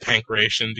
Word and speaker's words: Pankration: I'm calling it Pankration because that's how you say it phonetically.